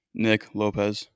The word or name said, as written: Nick Lopez